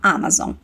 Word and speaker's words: Amazon: From Amazon, da Amazon. Notou como a pronúncia é diferente? Em português, a gente normalmente fala Amazon, mas em inglês a pronúncia é Amazon.